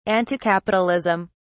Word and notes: anticapitalism: British English

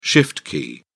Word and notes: shift key: British English

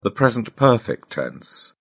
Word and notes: present perfect: British English

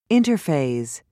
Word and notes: interphase: British English

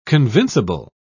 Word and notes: convincible: British English

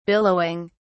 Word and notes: billowing: British English

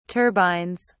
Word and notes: turbines: British English

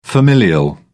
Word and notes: familial: British English

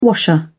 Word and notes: washer: British English

